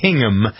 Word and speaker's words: Hingham: Is that how you say it? – I had dinner there a while ago. It's Hingham. It's interesting.